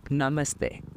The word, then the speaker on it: namaste: It's not namaste. It's namaste.